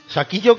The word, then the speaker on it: Shaquille: Saquillo como Shaquille O'Neal, pues se escribe así, pero Saquillo, españolizado.